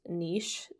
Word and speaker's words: niche: That's my niche. However, I think this website is pronounced niche just from what I've heard before, but whatever. It is spelled N-I-C-H-E.